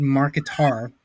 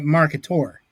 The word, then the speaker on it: Mercator: I'm pronouncing it Mercator, like a dinosaur. – Mm-hmm. Yeah. Mercator. That's the correct way.